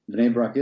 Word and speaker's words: Bnei Brak: Bnei Brak. You pronounced it perfect, Mike. Bnei Brak, Israel.